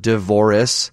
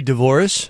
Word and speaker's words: divorce: Until I was about 15, I thought it was a three-syllable word, divorce, and like Dolores, but said like that, and someone had to correct me that it's divorce. – You would say Dvoris? – Dvoris, yes, like Dolores.